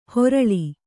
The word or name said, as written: ಹೊರಳಿ